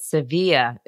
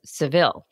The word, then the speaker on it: Sevilla: Appreciate that you said Sevilla instead of Seville, the American pronunciation.